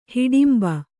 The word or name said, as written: ಹಿಡಿಂಬೆ